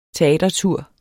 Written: teatertur

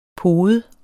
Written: pode

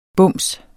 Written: BUMSENES

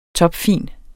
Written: topfin

